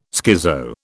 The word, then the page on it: schizo: British English